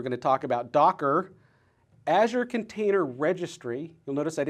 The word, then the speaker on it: Azure: So for those of you that are home wondering how you pronounce these things, Luis, L-U-I-S, Language Understanding Intelligence Services, pronounced Luis. You'll notice that I'm going to be saying Azure. – Not Azure. – Not Azure, which is fine, but if you want to pronounce it correctly, you'll say Azure.